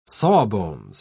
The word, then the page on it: sawbones: British English